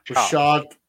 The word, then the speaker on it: Rashad: Lewis thought that we might see Rashid, why can't I say his name? Rashad Penny as an Eagles running back one.